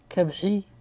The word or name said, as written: shelf